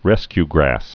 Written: rescue grass

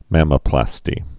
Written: mammoplasty's

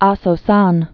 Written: Aso-san

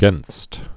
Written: gainst

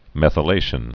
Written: methylation

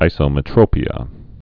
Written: isometropias

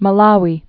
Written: Malawi

